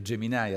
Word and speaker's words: Gemini: Allora, ce n'è un'altra eh, che è appunto Gemini o Geminai a secondo di come la vogliamo pronunciare, che cos'è?